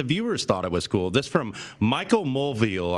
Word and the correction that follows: Mulvihill: on Twitter, if I'm pronouncing his name correctly. Michael Mulvihill, I should say.